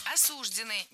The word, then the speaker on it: осуждены: То есть все эти фильмы, например, я думаю, совершенно не смотрятся для людей, которые осуждены на пожизненное заключение. – Осуждены. Неверно. Правильный вариант – осуждены. Ударение в этом слове ставим на окончание.